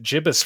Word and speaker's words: gibbous: I think the $2,000 of astronomy, this word for when the moon is between half and full can be pronounced with either a hard or soft G sound. I was unaware of that, but I think I'm just going to pronounce it gibbous from now on because that feels more fun.